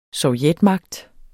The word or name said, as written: sovjetmagt